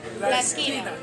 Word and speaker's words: La Esquina: How you pronounce the name? La Escuen. – La Esquina. – Esquina? – Yeah, the corner? – Yeah, the corner. La Esquina.